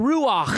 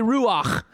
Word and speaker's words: ruach: The actual word is ruach. Everybody say ruach. But you got to say it like I got a cat hair stuck in the back of your throat. Ruach.